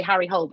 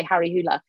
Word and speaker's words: Harry Hole: I think it's pronounced, lots of people say Harry Hole, but I think the kind of correct way is to say Harry Hula, who's a detective, and he kind of very much inspired Ellen in quite a few ways, actually.